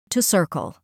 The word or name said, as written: to circle